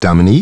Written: Dominique

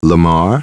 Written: Lamar

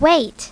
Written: wait

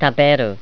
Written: taberu

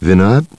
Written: Vinod

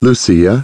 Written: Lucia

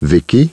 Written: Vicky

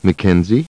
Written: Mackenzie

